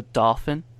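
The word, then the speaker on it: Dauphin: Next from my list, I believe this is pronounced the Dolphin. – That's how I always pronounce it as well. I don't believe that the word is ever said in the show. – Yeah. – So I think you're... That's how I always pronounce it in my mind when I saw it, because it looks like dolphin without the L.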